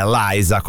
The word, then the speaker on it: Lisa: Ho detto Liza perché chiaramente la pronuncia di Lisa, come noi siamo abituati a dirlo in italiano, in inglese è Liza, come Liza Minnelli.